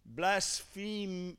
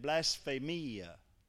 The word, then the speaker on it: blasphemia: Blasphemia is how you say it. Blasphemia.